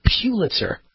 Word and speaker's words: Pulitzer: Well, I got it right this time, Chris. Pulitzer. Because Chris said the way you pronounce it is like a church pew. P-E-W. Pulitzer.